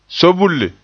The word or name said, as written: soobulle